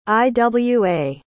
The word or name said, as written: Iwa